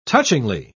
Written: touchingly

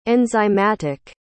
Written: enzymatic

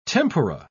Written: tempura

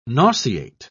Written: nauseate

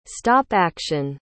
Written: stop-action

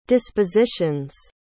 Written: dispositions

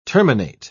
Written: terminate

